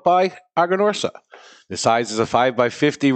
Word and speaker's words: Aganorsa: It's pronounced Aganorsa. – Aganorsa. – Aganorsa.